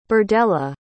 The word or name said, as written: birdella